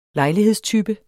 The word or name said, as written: lejlighedstype